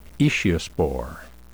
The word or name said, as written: aeciospore